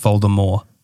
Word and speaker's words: Voldemort: Did you know that Voldemort is actually pronounced Voldemort? That it's a French word.